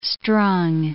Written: strung

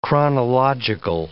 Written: chronological

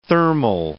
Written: thermal

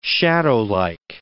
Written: shadowlike

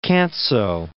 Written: Canso, Cape

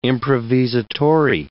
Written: improvisatori

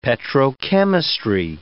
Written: petrochemistry

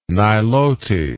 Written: Nilote

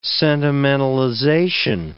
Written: sentimentalization

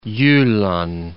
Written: Jylland

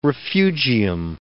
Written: refugium

